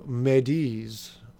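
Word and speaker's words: m'aidez: I don't speak French, so Médis, I believe is how it's pronounced, which literally translates to "help me" in English.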